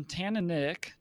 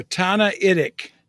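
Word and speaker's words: Tannaitic: But today, we're jumping into the first part of it, and it's on Tanaitic. Did I say that right? I don't think I quite did. – You got an extra N in there. Tanaitic.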